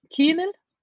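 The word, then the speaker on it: Kümel: How do you say his last name? Kumel? – I say like Kumel. – Kumel. – Kumel. – Kumel. – It's got an umlaut.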